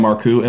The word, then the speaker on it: Marcoux: So once again, my name is Sam Marcoux, M-A-R-C-O-U-X. The X is silent. So if you ever try to pronounce it and, well, just don't fucking pronounce it.